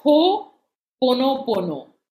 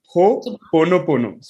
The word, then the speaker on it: Ho'oponopono: Ho'oponopono. Is that the right pronunciation? – Yeah, it's Ho'oponopono. – Okay. Ho'oponopono.